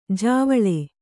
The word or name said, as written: ಜಾವಳೆ